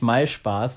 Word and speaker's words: MySpaß: Ich dachte immer, dass ich habe es immer Mai. MySpace ausgesprochen, aber es das heißt ja eigentlich MySpaß, was halt irgendwie total komisch ist.